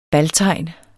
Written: baltegn

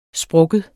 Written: sprukken

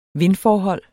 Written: vindforhold

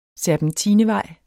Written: serpentinevej

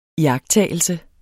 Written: iagttagelse

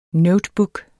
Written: notebook

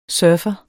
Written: Surferen

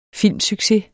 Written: filmsucces

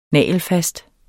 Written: nagelfast

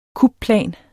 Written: kupplan

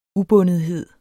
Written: ubundethed

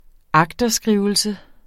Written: agterskrivelse

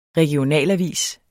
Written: REGIONALAVIS

